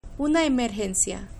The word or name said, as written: una emergencia